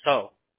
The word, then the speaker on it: Seo: You know how my last name is S-E-O, but it's pronounced so. – S-E-O?